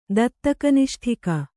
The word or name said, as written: ದತ್ತಕನಿಷ್ಠಿಕ